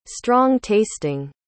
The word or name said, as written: strong-tasting